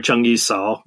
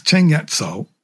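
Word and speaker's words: Cheng Yat Cao (鄭一嫂): You use the Cantonese pronunciation in your book. You don't call her Zheng Yi Cao. – No, she's Cheng Yat Cao. – And Zheng Yi is Zheng Yat. Zheng Yat.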